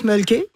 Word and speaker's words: Mölkky: On prononce Mölkky. – Ouais. – Et c'est M-O-L-K-K-Y.